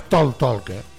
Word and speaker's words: talk: No, es talk. ¿Sí? O sea, la manera de pronunciarlo en inglés es talk.